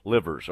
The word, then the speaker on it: Livers: Just to get you updated as you get ready and to watch your bracket start falling apart, Michigan's Isaiah Liver, how do you say that? He's a good, really good player. I don't want to say that wrong. Livers, okay. It sounded weird when it was coming out.